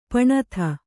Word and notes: ಪಣತಿ: paṇati

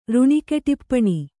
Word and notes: ಋಣಿಕೆಟಿಪ್ಪಣಿ: Kannada

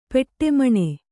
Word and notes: ಪೆಟ್ಟೆಮಣೆ: peṭṭe maṇe

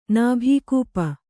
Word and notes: ನಾಭಿಕೂಪ: Kannada

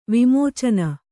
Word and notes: ವಿಮೋಚನ: Kannada

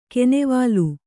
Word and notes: ಕೀನಿವಲ್ಲು: kīnivallu

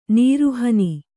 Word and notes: ನಾರುಹುಣ್ಣು: nāru huṇṇu

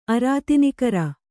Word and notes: ಅರಾತಿನಿಕರ: kannada

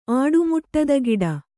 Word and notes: ಆಡುಮುಟ್ಟದಗಿಡ: āḍumuṭṭadagiḍa

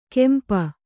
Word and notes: ಕಂಪ: kannada

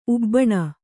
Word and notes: ಉಬ್ಬಿನ: ubbina

